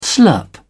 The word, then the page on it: slurp: British English